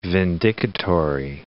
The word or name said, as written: vindicatory